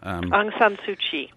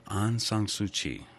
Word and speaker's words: Aung San Suu Kyi: Aung San Suu Kyi. – Aung San Suu Kyi.